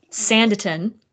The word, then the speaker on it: Sanditon: Is it Sanditon? – Yes. – Sanditon and The Watsons, I believe.